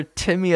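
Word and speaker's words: Tiqmmy: And you can find him on Instagram, Twitter, Facebook under Timmy as well.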